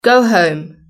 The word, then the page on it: go home: British English